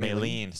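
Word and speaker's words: Malene: It's not Melanie, is it? – It's M-A-L-E-N-E. – Yeah, isn't it Maylene? – Sorry. I was thinking... – I was going to say, how the fuck are you pronouncing it? – I'm reading it M-A-L-E, but in my head I said it M-E-L-A. – Yeah. – Maylene. – Maylene, yeah.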